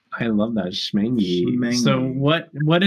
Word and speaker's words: Schmengie: It's spelled S-C-H-M-E-N-G-I-E. It has a C in it. – And it's Schmangy.